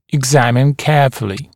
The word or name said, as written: examine carefully